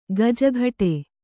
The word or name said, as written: ಗಿಜಿಗುಟ್ಟು